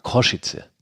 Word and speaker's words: Košice: Ganz Bratislava war eigentlich 2 Wochen Feierfest und jetzt kommt noch Košice mit hinzu. Richtig ausgesprochen, wie du es sagst, übrigens Košice habe ich gehört. – Košice. – Košice, dann werde ich das auch versuchen, so in Zukunft zu sagen.